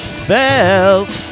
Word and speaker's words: Felt: Before that, Felt, which is actually properly pronounced Felt, because they're named after the way Tom Verlaine says Felt.